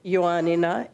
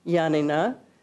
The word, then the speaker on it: Ioannina: And you can see it's spelled above in English letters, in the Greek, Ioannina, in formal Greek, in Demotic Greek, which is the Greek that people speak on the street every day, Yanina.